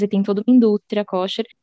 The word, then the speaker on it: kosher: Kosher ou kosher? Como é que fala? – Kosher. No hebraico é kosher, mas a gente fala kasher, que é a mesma coisa, né?